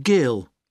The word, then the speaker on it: Gill: Yes, it's Mr. Gill.